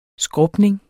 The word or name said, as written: skrubning